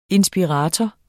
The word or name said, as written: inspirator